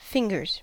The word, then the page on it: fingers: American English